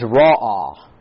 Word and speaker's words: ra'ah: Now the Hebrew word for grieved here is ra'ah. Ra'ah is how you pronounce it.